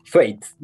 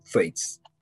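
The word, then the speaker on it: Thwaites: Tuaites, lo busqué. Vi así un video chiquitito para que cheques cómo lo pronunciaban. Y le dije Tuaites.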